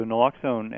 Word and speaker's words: naloxone: Yes, so naloxone, and you pronounced it correctly, is a miraculous antidote, really.